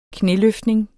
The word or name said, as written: knæløftning